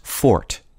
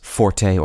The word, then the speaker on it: forte: There is some controversy over how to correctly pronounce forte or fort. Common choices in American English are forte and forte, but many usage commentators recommend pronouncing the word as fort.